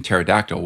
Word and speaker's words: pterodactyl: To give a really simple example, the reason you don't pronounce the p sound in psychology and pterodactyl, we don't say psychology and pterodactyl, is because English native words don't allow p plus an s or p plus a t at the beginning of a word.